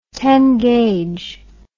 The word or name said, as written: ten gallon hat